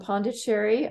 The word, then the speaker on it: Pondicherry: And she's a yoga teacher, was born in India in Pondicherry.